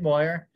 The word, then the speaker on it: Mawyer: M-A-W-Y-E-R. Moyer. – M-A-W-Y-E-R, yeah. M-A-W-Y-E-R. If you can spell that, Ethan Moyer, you can Google it.